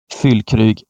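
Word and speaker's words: Füllkrug: Det finns, finns inget annat och Werder Bremen har ju ett potentiellt väldigt stort avbräck i skyttekung Fylkrug. Tyska uttal inte eller min starka sida. Eh, Fylkrug eh, som... – Det var perfekt.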